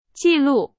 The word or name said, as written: 记录